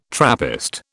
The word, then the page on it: Trappist: British English